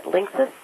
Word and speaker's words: Linksys: Z-Y-X-E-L. – Right. – D-Link. – Right. – Apple. Siemens SpeedStream. – Right. – And then Linksys. – Okay. – If I'm pronouncing that right. – I think you are.